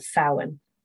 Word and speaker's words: Samhain: And what the Celts would have called Samhain. – Yes, but it's that time. Totally not spelled that way. – No. Yes, some people find it tricky to pronounce.